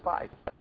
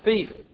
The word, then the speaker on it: fief: And there is a word, F-I-E-F, thief. I thought it was pronounced fife, but it's pronounced thief.